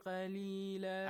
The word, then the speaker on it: قليلا: ثمنا قليلا أحسن thank you but قاف is also a heavy letter قا make sure you pronounce it correct